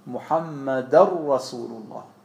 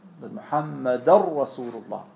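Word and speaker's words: Muhammadan Rasulullah: Deswegen sagt man, Muhammadar Rasulullah. Muhammadar Rasulullah. Man spricht kein N, man verdoppelt das Ra danach.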